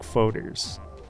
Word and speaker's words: foeder: Is it Foeders? – Yeah, it's a Foeder. You're exactly right.